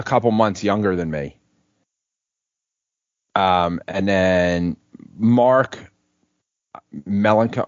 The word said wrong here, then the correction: Melanson: I can't pronounce Melanson. Yeah.